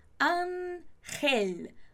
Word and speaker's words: ángel: Ángel. Ángel. Mucho cuidado con esa pronuncia. Ángel.